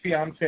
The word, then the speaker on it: fiancé: That's fiancé. It rhymes with Beyonce.